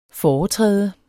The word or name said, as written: foretræde